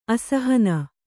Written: ಅಸಹನ

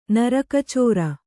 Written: ನರಕಚೋರ

